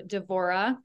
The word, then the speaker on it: Devorah: I am talking to Devorah.